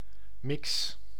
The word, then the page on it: mélange: France (Paris); /me.lɑ̃ʒ/